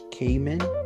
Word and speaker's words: Kahneman: And then for my book, I have a Thinking Fast and Slow by Daniel Kahneman.